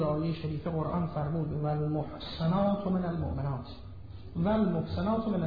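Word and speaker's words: المحصنات: اولا فرمود و المحسنات من المؤمنات، شما زنان محسنه مؤمنه، اینجا محسنات با سین نیست، با صاد تلفظ می شود،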